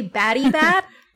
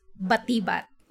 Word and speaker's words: Batibat: So they say batty bat. – Batty bat. – And how the locals say it is batibat.